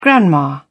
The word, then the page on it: grandma: British English